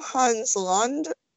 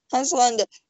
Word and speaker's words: Landa: But is his name Hans Landa? Like, L-A-N-D-A? Yeah. Okay. I'm making sure I was saying that correctly. Hans Landa, where you already feel like something's bad because you have Brad Pitt with his awful Italian accent, right?